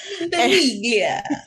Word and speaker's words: Ventimiglia: Jack played by Milo Ventimiglia, I believe that's how you say it. – Ventimiglia.